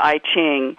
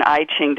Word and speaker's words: I Ching: becoming a dedicated student of the I Ching, and that is pronounced both I Ching and I Ching, depending on, you know, where you are in the world. But let's say I Ching for now.